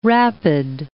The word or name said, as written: rapid